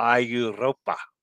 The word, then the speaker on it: Europa: For this ship name, I would go with the English pronunciation of Europa, since it's World Europa and not Mondo Europa. Roll the R. Europa.